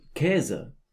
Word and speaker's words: Käse: A-umlaut sounds like... – Ä. – Ä. – And we find this in words like... – Lärm, for noise. – Lächeln, für smile. – And Käse, for cheese.